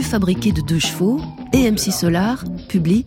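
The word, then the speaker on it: MC Solaar: le groupe Max Solar chante « Bouge de là ». – Max Solar, logique, ça s'écrit MC comme McCartney.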